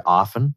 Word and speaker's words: often: By the way, a pet peeve of mine is the letter T in the word often is silent. Unless you're in the UK, or sometimes if you're reading poetry.